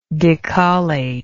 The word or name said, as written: decollator